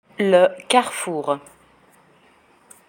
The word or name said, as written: le carrefour